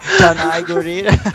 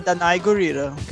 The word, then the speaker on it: Danai Gurira: Danai Gurira.